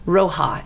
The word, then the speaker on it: Rioja: Rioja. It's spelled R-I-O-J-A. So Rioja.